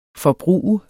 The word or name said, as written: forbruger